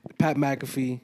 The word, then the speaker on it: Pat McAfee: Pat McAfee.